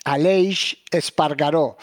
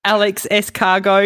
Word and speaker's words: Aleix Espargaró: Aleix Espargaró. – Oh, yeah, there's no way I would have got that. Do you want me to give you my version? Wait. Here's the version. Alex S. Cargo.